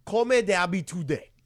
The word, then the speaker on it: Comme d'habitude: My Way is a song popularized in 1969 by Frank Sinatra, set to the music of the French song Comme d'habitude. – Oh, very well said. – That is really nice pronunciation.